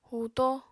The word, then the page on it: huuto: apatani